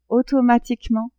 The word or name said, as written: automatiquement